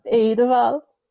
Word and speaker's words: Eidevall: Jonas Eidevall.